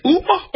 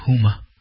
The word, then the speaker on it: Huma: Is it Uma or Huma? It's got an H, but I don't know how you pronounce it. I think it's Huma.